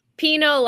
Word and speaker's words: Pineau: Is it Pineau? – Pinot like the wine.